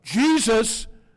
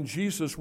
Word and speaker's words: Jesus: In 1611, Jesus wasn't pronounced Jesus. It would be with a yah, Jesus, much like Spanish.